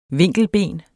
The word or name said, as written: vinkelben